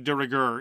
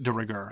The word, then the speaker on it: de rigueur: And now it's practically de rigueur in most. See, there you go. That's an example of a French expression that I don't pronounce with a French accent. De rigueur. I don't say de rigueur.